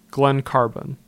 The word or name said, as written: Glen Carbon